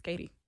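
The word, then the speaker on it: Keity: It's K-E-I-T-Y, and when they pronounce it, it's Katie.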